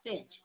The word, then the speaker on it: Finch: First name, last name is Finch, F-I-N-C-H.